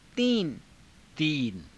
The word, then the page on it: tiina: Hindi